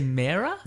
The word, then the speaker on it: Mera: Mera. How do you say it? – I say Mera. – Yeah, I say Mera as okay.